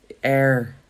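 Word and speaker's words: Aire: Air.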